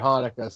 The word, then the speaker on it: Hanukkah: butchered Hanukkah so Chinoka.